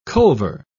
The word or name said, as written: culver